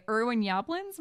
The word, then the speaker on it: Yablans: He gets picked up by Irwin Yablans.